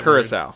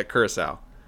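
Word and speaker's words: Curaçao: Lindsay said, Formula 86, the little blue perfume vial from The Witches, made into a drink, I don't know, something Curacao for sure. How do you say that Curacao. – Word? Curacao? – Yeah, Curacao.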